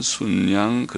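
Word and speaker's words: 숫양: 도우셨으므로 무리가 수송아지 일곱과 순양 일곱으로 순양은 순양 그렇게 발음하는데 순양 일곱으로 제사를 드렸더라.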